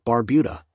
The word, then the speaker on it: Barbuda: Antigua, pronounced Antigua, and Barbuda, Barbuda, is located in the heart of the Caribbean Sea.